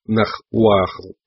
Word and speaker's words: Нагваль: Нагваль произносится как Нахуахл, название, дававшееся или животному, в которое маг мог превращаться, или тому магу, который практиковал такие превращения.